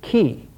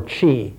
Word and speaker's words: qi: and that fifth is qi, or qi, it used to be pronounced. Qi is more correct.